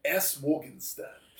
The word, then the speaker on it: Morgenstern: S. Morgenstern.